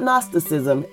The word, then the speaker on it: Gnosticism: Look up Gnosticism and see what that is all about. It is spelled with a G. The G is silent.